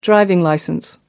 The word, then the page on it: driving licence: American English